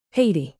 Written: haiti

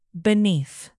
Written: beneath

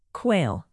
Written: quail

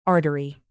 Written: artery

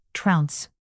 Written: trounce

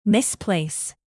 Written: misplace

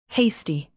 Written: hasty

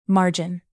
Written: margin

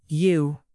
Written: yew

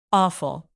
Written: awful

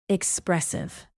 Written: expressive

